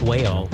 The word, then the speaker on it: Mhaol: They spell their name M-H-A-O-L, which apparently is pronounced whale.